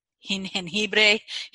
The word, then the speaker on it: jengibre: Y también podemos utilizar cosas que no son té, por ejemplo, jamaica, jinjen, jengibre y cosas así. Uh Jengibre. – No sé por qué yo quiero añadir esta sílaba extra al principio.